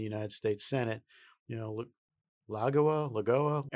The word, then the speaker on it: Lagoa: The chatter is it's going to be Amy Coney Barrett, ACB, or Barbara, I'm going to pronounce this hopefully right, Lagoa. – I think that's right.